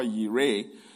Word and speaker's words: Jireh: Jehovah Jireh, Jehovah Yireh, some people pronounce it.